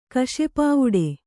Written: ಕಶೆಪಾವುಡೆ

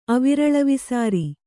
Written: ಅವಿರಳವಿಸಾರಿ

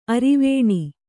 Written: ಅರೆವೆಣ್ಣು

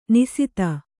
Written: ನಿಸಿತ

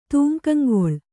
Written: ತೂಂಕಂಗೊಳ್

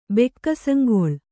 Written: ಬೆಕ್ಕಸಂಗೊಳ್

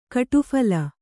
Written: ಗುಡಫಲ

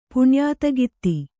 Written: ಪುಣ್ಯಾತಗಿತ್ತಿ

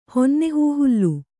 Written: ಹೊನ್ನೆಹೂಹುಲ್ಲು

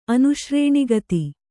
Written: ಅನುಶ್ರೇಣಿಗತಿ

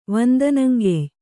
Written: ವಂದನಂಗೆಯ್

